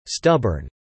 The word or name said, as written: stubborn